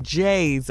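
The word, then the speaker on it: Jays: I grew up in Chicago, so it was J's all the way. Rhymes with Lay's, but it was J's in that blue and white bag.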